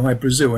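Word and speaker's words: Hy-Brasil: People pronounce it Hi, Brazil. And it's... – yeah, Brazil. Okay. – Yeah, yeah. But it's Hi, Brazil, because that's how the Irish would pronounce it, because it's an, okay.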